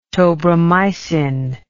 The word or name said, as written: tobramycin